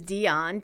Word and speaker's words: Dion: All right, the other suggestion I have is Dion, D-I-O-N, because it's kind of like Finn, although it has two syllables.